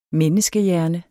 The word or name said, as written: menneskehjerne